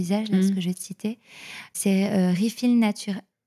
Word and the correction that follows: Refill Naturals: j'arrive jamais à prononcer, Refill Naturals en anglais, mais c'est une marque française.